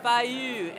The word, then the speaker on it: Fejø: Fejø is, am I saying that correctly, the name of the cidery, Fejø? – Fejø, and that's spelled F-E-J-O.